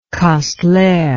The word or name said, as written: kastler